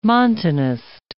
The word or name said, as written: Montanist